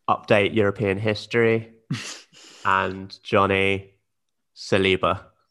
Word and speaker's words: Saliba: Saliba, yeah.